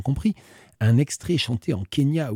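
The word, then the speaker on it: Quenya: Once again, le Kenya, mais ça ne s'écrit pas comme le pays, ça s'écrit Q-U au début.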